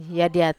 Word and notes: adiat: Wamesa

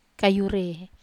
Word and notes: kayu re: wamesa

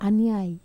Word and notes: aniai: Wamesa

